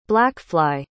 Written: blackfly

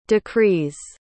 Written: decrees

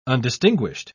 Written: undistinguished

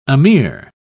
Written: amir

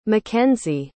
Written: mckenzy

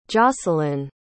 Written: joscelyn

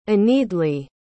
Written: aenedleah

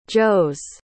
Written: joes

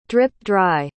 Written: drip-dry

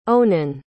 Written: onan

